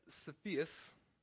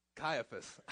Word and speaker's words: Caiaphas: Now, Annas and Caiaphas, that's how you say his name. Sophia? Caiaphas.